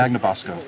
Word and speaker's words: Magnabosco: Your last name, but how do you say Magnabosco.